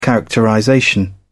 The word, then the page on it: characterization: British English